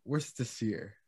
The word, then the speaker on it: Worcestershire: Because it's spelled Worcestershire, but it's pronounced. Worcestershire. – Yeah, I always try to pronounce the O and the R.